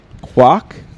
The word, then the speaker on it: Kwak: Is it K-W-A-K? – Yeah. – Yeah. – Quak? Quak is how it's pronounced. – Quak? – Quak is the correct way, but for some reason I can't say that right every time.